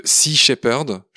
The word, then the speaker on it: Sea Shepherd: Lamia, on est venu aujourd'hui te voir, c'est pour parler de Sea Shepherd, je l'ai bien prononcé.